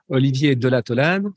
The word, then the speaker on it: Olivier Delataulade: I'm sorry if I pronounced that incorrectly, but please tell us your name, what you do, how you do it. – So, good morning. I'm Olivier Delataulade. – Yes, I said that wrong.